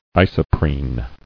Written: Isoprene

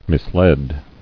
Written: Misled

